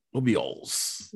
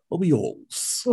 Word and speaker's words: Obiols: But is it Obiols? How do you say your last name? – It's Obiols. – Obiols. – Yes. – Okay. Obiols. Okay.